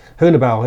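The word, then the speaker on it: Haunebu: you keep correcting my pronunciation on this. Hunibu? – Hunibau, yeah.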